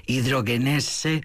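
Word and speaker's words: Hidrogenesse: me sugieren que dulcifique un poco la pronunciación, podría ser hidrogenese.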